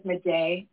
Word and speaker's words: Madej: Carrie Madej, and her last, it's C-A-R-R-I-E. She's a medical doctor in Georgia. Her last name is Madej, and it's spelled M-A-D-E-J.